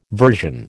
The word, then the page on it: version: American English